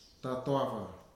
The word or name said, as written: Tatoafa